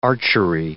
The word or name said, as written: archery